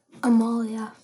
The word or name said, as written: Amalia